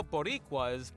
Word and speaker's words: Boricuas: To allow Boricuas to vote. How did I say that? – Good.